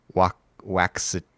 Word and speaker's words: Waxahachie: And the album is called I Walked With You Aways from Waxahachie.